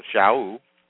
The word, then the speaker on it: Shaul: And then if you hold your place there and then turn to Romans, and this is another prophecy by the Apostle Paul, or Shaul, that's how you pronounce his Hebrew name.